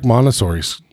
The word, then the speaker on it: Montessori: Well, it's like Montessori.